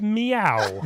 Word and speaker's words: Myau: Over a year ago, I couldn't pronounce that name. It was M-Y-A-U. It's Meow.